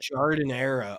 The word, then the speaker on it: Giardiniera: I don't know the right pronunciation of that, but Giardiniera.